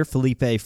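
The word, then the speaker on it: Feleipe: We have some news about likely our quarterback for next year, Felipe Franks.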